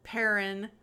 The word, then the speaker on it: Perrin: Got Mon Mothma and her husband, Perrin. – Which, have I mentioned yet? Perrin rhymes with Karen.